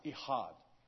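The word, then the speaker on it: Ihad (אחד): It's pronounced Ihad.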